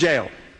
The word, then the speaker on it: Jael: By the way, it's J-A-E-L. It's pronounced four different ways. I just pronounce it Jael or Jael.